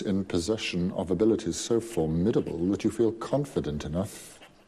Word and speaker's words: formidable: Znowu wypowiada się tutaj Snape i on używa tutaj przymiotnika formidable. Musiałam sprawdzić, jak to się wymawia, bo nie byłam pewna akcentu. Akcent jest na drugą sylabę. Zapisujemy to słowo jako formidable i ono znaczy ogromny albo godny podziwu.